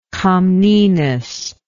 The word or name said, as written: comnenus